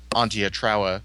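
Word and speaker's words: Antje Traue: and Antia Trawa, which is apparently how you pronounce the name.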